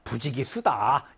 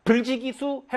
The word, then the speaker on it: 부지기수: 선생님, 이거 아니 불자 아닌가요? 그런데 보통 뒤에 오는 한자의 초성이 디귿이나 지으실 때는 발음하기가 힘들거든요. 불지기수 해봐요. 선생님, 지금 보이진 않겠지만, 불지기수 하는 순간 침이 팍 튀었단 말이죠. 발음하기가 어려워요. 그래서 뒤에 디귿이나 지읒으로 시작하는 말이 오면, 의리을 탈락시키고, 보통은 불로 읽는다라는 겁니다.